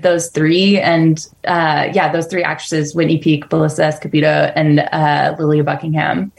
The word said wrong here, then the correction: Belisa Escobedo: Sorry, Belisa Escobedo. I mispronounced her name.